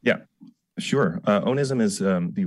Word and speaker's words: onism: Well, I want to bring up another one of your words, which I believe is pronounced onism. Am I pronouncing it correctly? O-N-I-S-M? – Yeah, that's right.